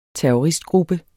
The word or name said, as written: terroristgruppe